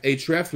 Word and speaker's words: Ahrefs: La deuxième astuce que je veux vous servir, c'est d'utiliser euh, Buzz Sumo ou encore SEM Rush ou encore HREF. HREF, là, ça s'écrit A-H-R-E-F-S.